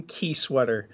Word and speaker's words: Kiesewetter: My name is Logan Kiesewetter.